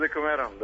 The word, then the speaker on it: Dekameron: Pa ja bih rekao Dekameron, da. Ali... Kao Dekameron, kao 10 dana, Dekameron.